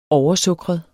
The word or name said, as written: oversukret